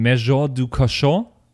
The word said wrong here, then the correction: Maison du Cachot: das war Maison, so falsch, wie es irgendwie geht. Maison du Cachot.